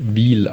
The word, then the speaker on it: Wil: Ich habe mir gerade die Folge vom letzten Exil-Hertaner angehört und da sprecht ihr auch über den Schweizer Zweitligisten FC Wil und da wird tatsächlich Wil ausgesprochen, nicht Will oder so wie ihr das da genannt habt.